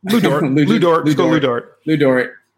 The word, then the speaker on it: Lu Dort: Look, all I'm saying is that if he's going to be like PJ Tucker or, you know, even a Lugin Stortz, sorry, I don't know if I'm saying his name right, my bad. – Lu Dort.